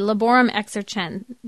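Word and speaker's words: Laborem Exercens: Laborum Exercense.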